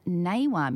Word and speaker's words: Gnawa: And Abdul Majid Bekkis is a Moroccan Naywa musician. That's spelled G-N-A-W-A.